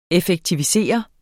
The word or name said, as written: EFFEKTIVISERE